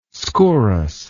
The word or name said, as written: skouras